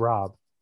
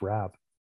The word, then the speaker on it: Raab: No, it's not pronounced Rob. – Oh, it's not? – No, it's pronounced Raab.